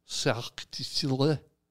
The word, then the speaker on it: Cirque du Soleil: That's how you, a true French. Say it. How do you say it? – Cirque du Soleil. – You got to take Cirque du Soleil. It's almost like you drop a few letters.